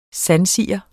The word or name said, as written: sandsiger